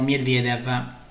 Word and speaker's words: Medvedev: il match di Danil Medvedev o Medvedev, se preferite la pronuncia russa, contro Tommy Paul.